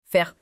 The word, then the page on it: faire: French